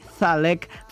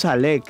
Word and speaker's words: Zalek: Claro, porque es que en Colombia no tenemos el ceseo así de la Z tan excelente como acá, que es en Zalek. Ahí decimos Zalek.